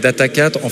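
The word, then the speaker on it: Data4: Donc moi, je prononce en fait Data 4, en fait, avec le... puisqu'on est très local, on dit Data Quattro, Data 4, Data Sphere.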